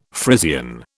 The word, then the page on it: Frisian: British English